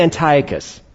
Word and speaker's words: Antiochus: I pronounced it my way last week. Now let me try to pronounce it the correct way this week. I said Antiochus. I'm going to repronounce that right now, because you have so much grace for me. I'm going to say Antiochus this time. All right, Antiochus, all right? It's all about which syllable you accent, all right?